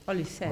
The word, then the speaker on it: Olise: O sea, ahí yo sé que de repente puede ser un partido cerrado, pero si andan activados Mbappé, Olise, no sé cómo se dice. – Olise.